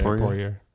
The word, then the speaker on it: Poirier: You got Oliveira versus Poirier. Is that how you say it? Poirier.